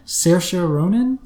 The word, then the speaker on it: Saoirse Ronan: I'm gonna mispronounce it, and you're gonna be like, it's actually Saoirse Ronan.